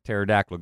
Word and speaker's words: pterodactyl: Brock Marci, how come you can never hear a pterodactyl go to the bathroom? – Why? – Because the P is silent.